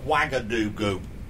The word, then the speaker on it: Ouagadougou: And it's pronounced Ouagadougou.